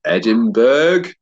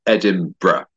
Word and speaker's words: Edinburgh: Edinburgh is one of those places that's very difficult to pronounce because everybody wants to say Edinburgh, but it's not very logically written. So Edinburgh. Yeah, I studied biomedical science,